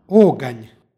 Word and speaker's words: oganj: U istom stihu pročitali smo oganj sa izgovornim a u drugom slogu.